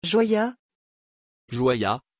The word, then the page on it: joya: French